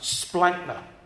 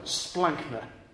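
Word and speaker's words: splankna (σπλάγχνα): It's actually pronounced splankna. What a wonderful word, splankna.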